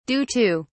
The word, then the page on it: due to: British English